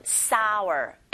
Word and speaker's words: sour: 이 soar 자체가 치솟다라는 soar 발음하실 때 soar 이렇게 발음하시고 얘랑 비슷하게 생긴 단어 철자 다르지만 구분하셔서 알아두셔야 되겠죠. 요건 sour, S-O-U-R, sour, 맛이 신이란 뜻으로 구분해서 알아두시기 바랍니다.